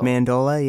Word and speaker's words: mandola: Is it mandolo or mandolo? – Mandola, yeah. – Oh, uh, mandola. – There's yeah, not even yeah. an O at the end.